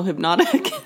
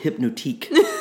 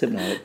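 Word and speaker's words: Hypnotiq: So I used to call it Hypnotique. And I thought that's how you're supposed to say it.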